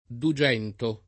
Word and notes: dugento: lingua italiana

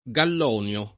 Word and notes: Gallonio: lingua italiana

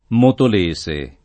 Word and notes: Motolese: lingua italiana

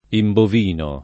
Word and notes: imbovinare: italiana

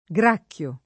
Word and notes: gracchiare: italiana

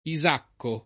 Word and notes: Isacco: lingua italiana

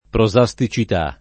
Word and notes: prosasticità: lingua italiana

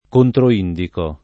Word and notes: controindicare: lingua italiana

